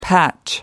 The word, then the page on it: patch: American English